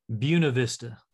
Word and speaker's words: Buena Vista: Because I'm not a Colorado native, and I say Buena Vista, but that's not it, is it? – Buena Vista. – Buena Vista. – Okay. So say it the Colorado wrong Spanish pronunciation, like we do with so many things, like Salida and Lyman.